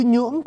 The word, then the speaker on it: nhưỡng: Bây giờ nếu đổi chữ thổ kế bên nó ra chữ nhưỡng quý vị. Bây giờ mình đọc là NH á, thật ra sai. Âm cổ là âm NR này là nhưỡng, là bây giờ gọi là thổ nhưỡng đó quý vị.